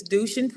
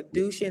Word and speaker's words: Dushin: Is Dushin Prien. So to help you out, it's Du and then Shin, like the shin on your leg. So Dushin, and then last name is Prien.